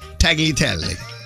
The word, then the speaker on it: tagliatelle: But in Italian, the G-L-I is pronounced G, like a G, like glee, but in the back of the throat, G, tagliatelle.